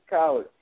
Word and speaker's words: College: Absolutely. Like post-secondary education, college.